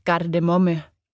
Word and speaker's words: Gardemomme: Og Henning fik efternavnet Gardemomme. Nanne udtaler gardemomme med et spansk R. Det er meget vigtigt, ellers minder det alt for meget om bare kardemomme.